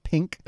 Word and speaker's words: P!NK: And can you, however you pronounce that, I believe you pronounce that pink.